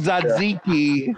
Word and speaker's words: tzatziki: It's called tzatziki. But somehow the internet. Well, that's what people, like Greeks, would say tchatchiki.